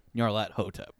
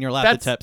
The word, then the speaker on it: Nyarlathotep: I've always said it Nyarlathotep. – I was going to say. – But I figured since I'm going to actually put this out there, like I know the proper way to pronounce it is Nyarlathotep. But I think Nyarlathotep sounds better.